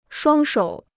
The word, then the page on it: 双手: shuāng shǒu